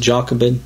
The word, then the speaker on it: Jochebed: What about Jochebed? Have you ever heard of Jochebed? Some pronounce it Jochebed. Jochebed.